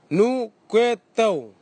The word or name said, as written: nukwetau